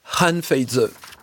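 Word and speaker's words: Han Feizi: On va écouter, Romain Grazani, un texte, vous me dites si je le prononce mal, de Han Feizhe. – Han Feizhe.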